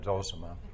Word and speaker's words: Zosima: And the person that helps Allelusa deal with these issues is Father Zosima, or Zosima. Being from Texas, I'm limited in pronouncing Russian, but I've always said Zosima. Some people say Zosima,